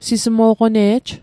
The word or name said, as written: sismo'qone'j